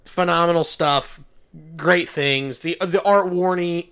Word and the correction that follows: Rooney: Art Rooney, excuse me.